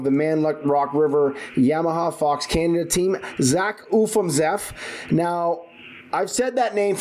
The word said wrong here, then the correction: Ufimzeff: Gotta get rid of the ooh. – I got it. I sound too Canadian. – Yeah, exactly. – That's okay. It's Ufimzeff, not Ufimzeff. Okay.